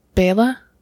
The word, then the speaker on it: Bela: Bela. I said Bella. – Bella is the girl in Twilight. – Oh, I understand. Bela I'll go nose and toes and think about what I did.